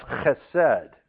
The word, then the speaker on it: chesed: In Hebrew, that's chesed. You would spell it H-E-S-E-D. And it talks about covenant faithfulness. It is always associated with the divine covenants God makes with Israel. The word chesed occurs widely with a variety of meanings.